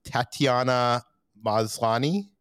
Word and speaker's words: Tatiana Maslany: Tatiana Maslany.